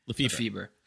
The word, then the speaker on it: Lefebvre: Lefebvre.